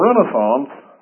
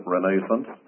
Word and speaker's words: Renaissance: And the Renaissance, our British friends sometimes pronounce it Renaissance, but the Renaissance led ultimately into the Reformation period of time.